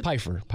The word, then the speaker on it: Pfeiffer: Pfeiffer, how do you pronounce it? – Pfeiffer. Pfeiffer Elementary. – Pfeiffer. It's a weird spelling.